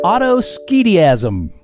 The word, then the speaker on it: autoschediasm: And the word for round 6 is autoskediasm. – Oh, no. – Oh, dear. – Yeah, that's right. Autoskediasm. That's spelled AUTOSCHEDIASM. Autoskediasm.